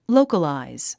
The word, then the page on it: localize: lóukəlàiz; ロウカライズ